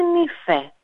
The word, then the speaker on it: νύφε: Έτσι λοιπόν, έχουμε την λέξη νύφη, που στα ποντιακά τη λέμε νύφε.